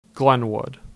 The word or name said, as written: Glenwood